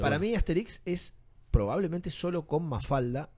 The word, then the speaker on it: Asterix: No, es Asterix. – ¿Es Asterix? – Sí, con el acento en la última sílaba.